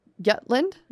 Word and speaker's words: Jutland: Jutland? – Yeah.